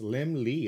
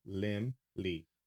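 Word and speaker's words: Laemmle: He said his name means little lamb and is pronounced as if it were spelled Lim Lee. L-E-M-L-E.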